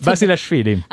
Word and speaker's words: Basilashvili: superato in 5-7 dal georgiano Nikolaos Basilashvili. Non so se l'ha detto. – Basilashvili. – Ah, perdona, ho messo male l'accento. Comunque, Travaglia è stato bravo a sfruttare ogni occasione possibile, salendo avanti 2-7-1,